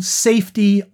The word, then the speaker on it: SAEFTY: The group is called Safety Ottawa. That's spelled S-A-E-F-T-Y, and it stands for Support and Education for Trans Youth.